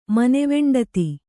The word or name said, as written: ಮನೆವೆಂಡತಿ